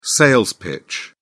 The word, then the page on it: sales pitch: British English